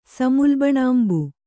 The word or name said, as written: ಸಮುಲ್ಬಣಾಂಬು